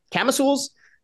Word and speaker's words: camisole: Am I saying it right? Take it away. – You are not saying it right. It is pronounced camisole.